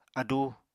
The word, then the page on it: adú: Apatani